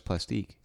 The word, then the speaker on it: Plastique: He's Belgian, but he sings in French and English. – That's why it's Plastique.